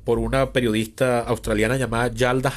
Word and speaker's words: Yalda: Hakim, o sea, Yalda se escribe con Y, como suena, Yalda, y Hakim o Akim se escribe con H, H-A-K-I-M.